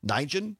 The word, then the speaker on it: Nguyen: I'm probably going to butcher her last name pronunciation. Is Nguyen Nijen.